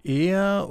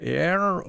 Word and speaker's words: Aire: Der, das ganze Buch heißt nun Er oder Er oder Aire, je nachdem, wie man das aussprechen will, Englisch, Französisch, Spanisch, gibt es verschiedene Möglichkeiten. A-I-R-I geschrieben, dieser Titel.